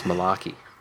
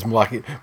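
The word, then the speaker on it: Malakai: And it's actually pronounced Malarkey. – Well, I mean, it is Malarkey.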